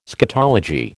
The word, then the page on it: scatology: American English